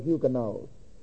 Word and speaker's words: Huguenots: Also in 1560, you had the persecution of the Huguenots. H-U-G-U-E-N-O-T-S. Some people pronounce it Huguenot. Huguenot, Huguenot apparently seems to be correct, either of the two.